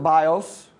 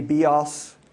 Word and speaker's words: bios: We would pronounce it bios. In the Greek, it may be bios.